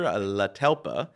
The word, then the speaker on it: Tlatelpa: We are super excited to have with us today Peter Tlatelpa, of the Doctor Real Estate and Leyva team.